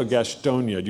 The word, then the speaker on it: Gastonia: How do you say it? What's the proper way to say it? Gastonia or Gastonia? Do you add a little nya? Okay, Gastonia. All right. What'd you say? Okay, I'll stick with Gastonia.